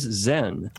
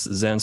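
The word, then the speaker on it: Xen: My name is Zen. That's Zen spelled with an X, X-E-N.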